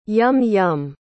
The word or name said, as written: yum-yum